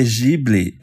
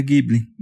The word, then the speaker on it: Ghibli: Porque Ghibli é a pronúncia japonesa, mas a palavra é de origem italiana, que é com gui. Então, no Japão se fala Ghibli, mas eles pegaram uma palavra italiana que é Ghibli.